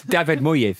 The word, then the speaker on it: Moyes: It's David Moyes, isn't it? – Moyes, correct pronunciation.